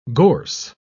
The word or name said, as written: gorse